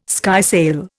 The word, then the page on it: skysail: Indian